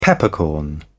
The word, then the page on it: peppercorn: British English